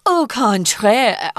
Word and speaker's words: au contraire: au contraire, which is French and it means the same thing.